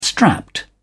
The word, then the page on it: strapped: British English